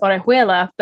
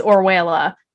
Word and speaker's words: Orejuela: I know sometimes people who have last names that sound, you know, or, like my last name is Orejuela, but I go, I say it's Orwella, even though that's not the way that you pronounce it.